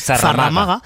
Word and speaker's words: zarramaga: Pero hay más leyendas, por ejemplo esta de la zamarraga, la zarramaga. – Sí. – Perdón, que lo he dicho mal, siempre digo zamarraga, pero bueno, es zarramaga.